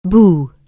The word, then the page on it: boo: anglais